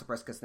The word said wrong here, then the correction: Zebstrika: name correctly, so Zebstrika. – Thank you, Zebstrika.